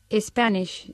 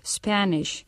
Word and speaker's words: Spanish: Y por eso es que muchas veces los estudiantes de inglés pronuncian Spanish en vez de decir Spanish. Y deben de tener cuidado, lo mismo que con school o otras palabras que empiezan con S. No ponerle esa E porque no debe ir una E adelante.